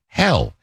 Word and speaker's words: Hel: And they have a 13-hour bus ride from one city to the city of Hell, H-E-L, but pronounced Hell.